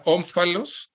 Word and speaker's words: Ómfalos: Se pronuncia Ómfalos, como de ombligo.